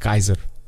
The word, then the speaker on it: Caesar: Caesar, Julius Caesar, that word Caesar is actually pronounced Kaiser. – Really? – Yeah, Kaiser. In their language. Like, if you lived in, you know, like, speaking Latin like that, you would have said Kaiser.